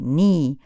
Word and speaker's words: knee: Notiamo come la K seguita dalla N in inglese sia muta. Si scrive knee, ma si legge knee, ginocchio.